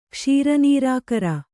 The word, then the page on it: ಕ್ಷೀರನೀರಾಕರ: Kannada